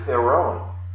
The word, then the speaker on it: Theron: So the Greek word is Theron, just like Charlize Theron, the actress.